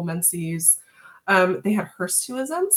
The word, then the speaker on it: hirsutism: A physician that I worked with said, it's like a suit. Hirsutism. Hirsutism. – Oh, I think I did say it right. Hirsutism.